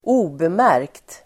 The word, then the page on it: obemärkt: ²'o:bemär:kt